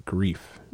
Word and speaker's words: Greef: It's K-A-R-G-A, so sorry if I'm pronouncing. – Grave Karga? – Grief. – Grief? – G-R-E-E-F. Grief.